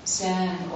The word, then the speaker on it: Zen: Okay, so the koan way, which is a form of Zen or Chan. Chan is the Chinese pronunciation of the word that's pronounced Zen in Japanese.